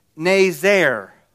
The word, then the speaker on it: nazer: The Hebrew word for branch is pronounced nazer. So Isaiah says,